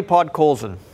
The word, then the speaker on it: Podkolzin: Vasily Podkolzin.